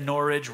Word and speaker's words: Norwich: And so, of course, it's Norwich. And I've learned the only way I can remember it is that Norwich rhymes with porridge.